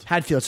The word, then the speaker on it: Hadfield: Man, how do you say his name? Chris Haddonfield? – Hadfield, sorry.